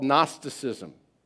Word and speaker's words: Gnosticism: It was called Gnosticism. Gnosticism, spelled with a G.